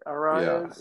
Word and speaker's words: Arraez: the lightning rod players would be Luis Arraez. Is that how you say it? Arraez? – Yeah, second.